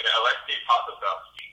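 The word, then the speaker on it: Aleksej Pokuševski: Can we get the correct pronunciation of that? Alexander, is that Polkow? – Alexei Pakusevelski, is that the one? – Alexei Pakusevelski, yes.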